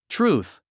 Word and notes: truth: trúːθ; トゥルース

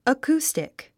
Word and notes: acoustic: əkúːstik; アクゥスティック

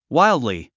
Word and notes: wildly: wáildli; ワイルドリィ

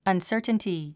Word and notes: uncertainty: ʌnsə́ːrtnti; アンサァタンティ

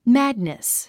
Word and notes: madness: mǽdnis; マァド二ス

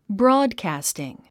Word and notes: broadcasting: brɔ́ːdkæ̀stiŋ; ブロードキャスティン

